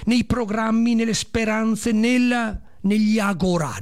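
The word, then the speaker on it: Agorà: Agora, con l'accento sulla A, sulla A.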